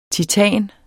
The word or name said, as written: TITANERNES